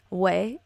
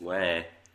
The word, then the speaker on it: ouais: But yeah, did you know that when they say way in Quebecois French, it's spelled O-U-A-I-S? Oh my gosh, I'm yep. so sorry. – Yes. – It's way.